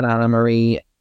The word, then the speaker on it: Anne Marie: This current season of Beverly Hills, Anne Marie, or Anna Marie, she spells her name Anne Marie, she pronounces it Anna Marie.